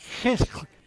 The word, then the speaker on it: Chislev: but the pronouncing it is just, it's Chislev. I want to get it right, Chislev, you know.